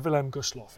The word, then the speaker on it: Wilhelm Gustloff: Here's Roger Morehouse and the sinking of the Wilhelm Gustloff. Enjoy. Roger, did I pronounce it correct? – Just about, Dan. Yeah, that was fine.